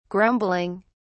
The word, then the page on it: grumbling: British English